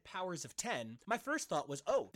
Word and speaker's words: Powers of X: While both are written and stylized as House of X and Powers of X, they are properly pronounced House of X and Powers of 10.